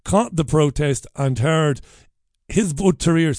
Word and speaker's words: Hizb ut-Tahrir: So they said jihad, these Hizb ut-Tahrir, that's how you say it.